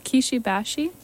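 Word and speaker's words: Kishi Bashi: Kishibashi.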